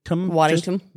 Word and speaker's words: Waddingham: I said Waddingham. – Waddingham. – Did I just say that right there? – You did.